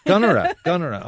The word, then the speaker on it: Gunnera: How do you pronounce it? – Gunnera.